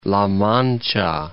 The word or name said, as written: La Mancha